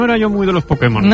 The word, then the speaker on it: Pokémon: El poder que hay en Pokémon. – Pues hazte con todos, Pudemon. – Te diré que es difícil decirlo peor.